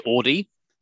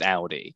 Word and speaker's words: Audi: some people will say Audi, which is not actually the correct pronunciation. It's Audi. And so a lot of the time it was about getting that ow sound, the AU, the ow.